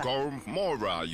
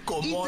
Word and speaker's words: Gomora: Gormora United. – This is not Gomora. It's a silent R. – Gomora.